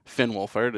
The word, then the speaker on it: Finn Wolfhard: Finn Wolfhard has been in...